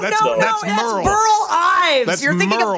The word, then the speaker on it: Beryl: And by the way, I don't want to get off topic, but B-E-R-Y-L is pronounced Burl. – Wait a minute. Burl? – Isn't it? – If you're from Tonganoxie. – Burl, that's how you say it in Tonganoxie. Old Tonga, you say Burl.